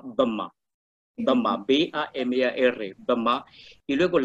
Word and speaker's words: Bamar: La mayoría etnia se llama Bama, Bama, con B-A-M-A-R. Se pronuncia Bama.